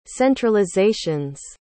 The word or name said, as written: centralizations